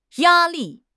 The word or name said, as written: ya1 li4